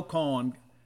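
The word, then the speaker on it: Kohn: Bottom of six, Pico Khan, rhymes with John, came in.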